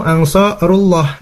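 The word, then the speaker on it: أنصار الله: Baru yang kedua adalah nahnu ansarullah. Nah, itu betul ya. Yang keduanya betul. Adik saya bacanya ru ya, rullah.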